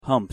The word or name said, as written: humped